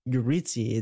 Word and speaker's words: Yuritsi: It's like, if you're trying to like insult someone, like Yuritsi, if you're trying to insult her because she's Hispanic, you don't need to mispronounce her name. It's Yuritsi.